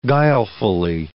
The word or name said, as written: guilefully